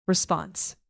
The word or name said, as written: response